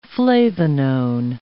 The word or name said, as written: flavanone